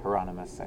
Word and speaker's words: Hieronymus: Hieronymus, I think, is how you say his name.